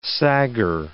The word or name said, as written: saggar